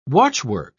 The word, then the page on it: watchwork: British English